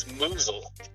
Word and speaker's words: Musel: You are very close. It's Musel. – Musel, yeah. – Right? – Yes, sir. – Emphasis on the moose, right? – Yeah, the moose.